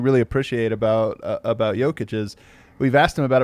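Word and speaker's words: Jokić: We all say Nikola's name wrong. It's Nikola, and it's not even Jokic. It's Jokic.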